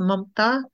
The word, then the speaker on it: Mamta: It's actually pronounced MAMTA in the language.